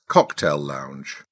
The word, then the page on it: cocktail lounge: British English